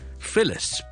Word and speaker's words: Phyllis: Felix F-E-L-I-X 这个英文名同埋 Phyllis P-H-Y-L-L-I-S 这两个发音有什么分别呢？